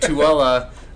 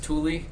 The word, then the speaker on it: Tooele: and, and, and Tooele looks like it's it should be pronounced Thule. Thule.